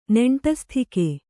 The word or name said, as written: ನಂಟಸ್ತಿಕೆ